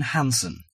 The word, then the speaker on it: Hansen: Martin Hansen. – Do you spell that S-O-N or S-E-N? – H-A-N-S-E-N.